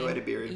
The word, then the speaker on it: Ayo Edebiri: Ayo Edebiri.